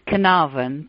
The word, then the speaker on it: Carnarvon: Well, in England it's Carnarvon, but in America it may be slightly different.